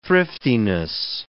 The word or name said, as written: thriftiness